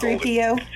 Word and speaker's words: C3PO: 3CP. I never can say his name. The guy that's C3PO. – There you go.